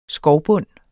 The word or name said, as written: skovbund